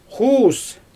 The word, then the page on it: xuus: Xyzyl